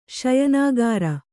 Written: ಶಯನಾಗಾರ

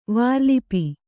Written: ವಾರ್ಲಿಪಿ

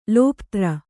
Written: ಲೋಪ್ತ್ರ

